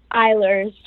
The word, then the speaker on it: Eilers: Eilers.